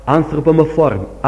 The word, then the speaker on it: anthropomorphism: Well, this is what grammarians speak of as anthropomorphism.